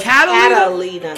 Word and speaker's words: Catalina: He just had this whole thing with Carolina. – Mm-hmm. What Catalina. You always say it wrong. Say it right. – Carolina. – Catalina. – No, it's Catalina. – Catalina. C-A-T. Not Carolina.